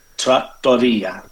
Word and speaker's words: trattoria: How do you say Trattoria.